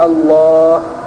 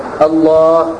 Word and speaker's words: الله: هذا لا يجوز، لأنه لا يذكر الله بأه ولا بأه ولا بالله، إنما يقال الله، هذا اللفظ الصحيح، همزة ثم تليها اللام المشددة ثم بعدها لام أخرى ثم ألف تنطق ولا تكتب ثم هاء، الله، هذا اللفظ الصحيح.